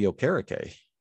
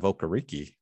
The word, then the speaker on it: Okereke: Um, and you know, apparently his his pronunciation changed. If you've watched, uh, was it Monday Night Football? But the linebacker for Indianapolis, Bobby Okereke, instead of Okariki, which I love saying Okariki, but they uh, they said he likes to go by Okereke now.